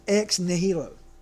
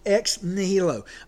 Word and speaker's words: ex nihilo: is sometimes described by the Latin phrase ex nihilo. Okay, sometimes I mispronounce that, but ex nihilo or nihilo. It's actually spelled E-X-N-I-H-I-L-O.